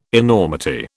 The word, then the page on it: enormity: British English